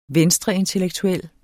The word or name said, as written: venstreintellektuel